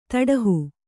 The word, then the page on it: ತೊಡೆಹ: toḍeha